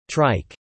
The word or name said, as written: trike